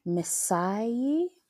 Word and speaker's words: Misaie: Which is a fashion brand called I don't even know how to pronounce it. Let me see if I can find it. Misaie. – Mm-hmm. – It's spelled M-I-S-A-I-E-I.